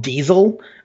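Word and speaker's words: DSSSL: There was one, for example, called Diesel. I think that's how it's pronounced. It's D-S-S-S-L.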